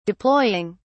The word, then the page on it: deploying: British English